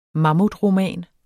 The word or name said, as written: mammutroman